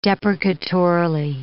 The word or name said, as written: deprecatorily